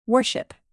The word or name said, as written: worship